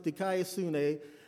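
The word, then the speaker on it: dikaiosune (δικαιοσύνη): It's a word pronounced dikaiosune.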